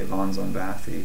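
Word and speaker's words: von Szombathy: It depends, actually, on if you're going by the original Hungarian pronunciation or the English pronunciation. But I think that's actually pretty close to the Hungarian one. I just usually say von Zombathy, but okay. It's... You know, it kind of changes depending on who actually is doing it and who's spelling it.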